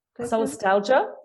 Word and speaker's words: Solastalgia: Solastalgia. – Solastalgia.